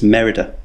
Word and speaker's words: Merida: Well, this, they pronounce it in a Scottish accent, and I can't do Scottish accents. It's Merida.